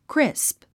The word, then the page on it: crisp: krísp; クリスプ